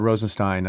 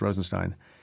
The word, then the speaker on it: Rosenstein: First, as for whether or not Rod Rosenstein, I think it's pronounced Rosenstein, should